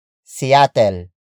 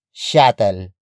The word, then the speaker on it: Seattle: It is the Tagalog pronunciation for Seattle, which is located in Washington State in the Pacific Northwest. Some Tagalog speakers may pronounce it as Shattel. Shattel. Shattel. This is mainly due to the S-I-Y letter combination, which is commonly pronounced as sh, like shout.